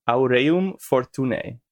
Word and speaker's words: Aureum Fortunae: Aureum Fortunae, spelled like Latin.